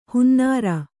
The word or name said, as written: ಹೊನ್ನಾರು